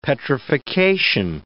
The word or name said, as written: petrification